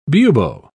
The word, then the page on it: bubo: British English